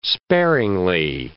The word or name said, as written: sparingly